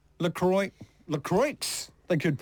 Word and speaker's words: La Croix: It's like that fancy, I don't know if you know it, the sparkly, fizzy, non-alcoholic drink out of America, which is spelt L-A, new word, C-R-O-I-X. – Oh. – Should be La Croix.